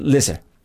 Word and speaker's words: Leicester: Leicester. – It's pronounced Lisa.